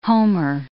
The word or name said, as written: Homer